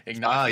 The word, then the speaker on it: Ignacio: Ignacio.